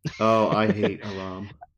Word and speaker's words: Aram: But I think this is after, that comes after Amaran comes to dinner. – Aram? – Aram. Oh, Aram? – Yeah, A-R-A-M, Aram.